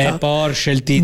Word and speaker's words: Porsche: Il nome corretto è quello della Porsche e si pronuncia in due sillabe. Porsche. – Sce. – Sce. Quella E finale non è muta. – No. – Si sente come... e come come un piccolo respiro finale.